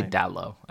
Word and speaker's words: Dalot: Do you pronounce the T or not? – I, I'm not Portuguese. – Well, but I would say Dallo. I think it's Dallo. So, I think no T.